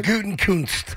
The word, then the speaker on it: Gutekunst: Gutekunst.